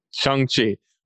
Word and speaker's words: Shang-Chi: Shang-Chi. – Well, because it was like Sean, like he's Sean. Like Sean, your yeah. name is Shang-Chi.